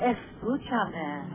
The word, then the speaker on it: Escúchame: S.Q. Shame? Is that how you say it? – Which? – S.Q. Shame, the one you were telling us about? – Oh, Escúchame.